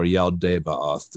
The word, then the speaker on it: Yaldabaoth: a monster named Yaldabaoth, or Yaldabaoth, depending on how you want to pronounce it.